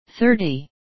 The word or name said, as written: thirty